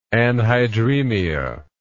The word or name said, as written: anhydremic